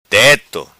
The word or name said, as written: Teto